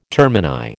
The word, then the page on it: termini: American English